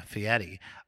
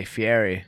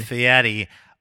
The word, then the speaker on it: Fieri: Thank you for pronouncing his name correctly. – I know how to pronounce it. Yeah, Fieri. – A lot of people come up in here and say Fieri. And I say, get that shit out of here. Pronounce the man's name correctly.